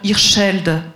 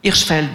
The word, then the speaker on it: Hirschfeld: Le patient du docteur Hirschfeld, je n'ai pas bien prononcé, mais... – Hirschfeld, merci, qui a reçu le prix du public RTS et paru en 2011, tous chez Bernard Campiche.